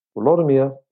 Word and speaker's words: Volodymyr: Volodymyr, som jo øh, øh også er den, den moderne ukrainske måde at udtale navnet på, øh, Vladimir på moderne russisk, og på dansk, altså Valdemar.